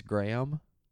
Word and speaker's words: Graham: That name is pronounced with two syllables. It's Graham. Because That happens to be my middle name, so I hope you appreciate it.